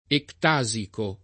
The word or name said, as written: ectasico